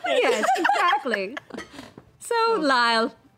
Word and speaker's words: Lisle: So when I got the card, the name was spelled L-I-S-L-E. – Yeah. – Right? And I decided it was Lyle. A-I-S-L-E is Isle, so L-I-S-L-E should be Lyle.